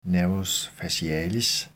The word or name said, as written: n. facialis